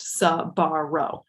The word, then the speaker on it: Sbarro: It is correct. Sbarro.